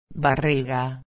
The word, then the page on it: barriga: espanhol